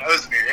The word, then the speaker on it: Ozdemir: But DC would have three title defenses: Gustafsson, a second fight against Rumble Johnson, and then Volkan Ozdemir. I'm butchering that name. – You're good. Ozdemir.